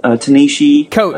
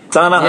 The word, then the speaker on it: Ta-Nehisi Coates: I love watching you tiptoe around on eggshells when it's like, what was the guy who wrote, what was Ta-Nehisi Coates? – What's his name? – Ta-Nehisi Coates. I got it now. Ta-Nehisi. – Ta-Nehisi.